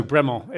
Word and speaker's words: Bremont: But it's a bit of a silent T. So Bremont is the... – More of the French.